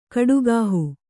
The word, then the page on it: ಕಡುಗಾಹು: Kannada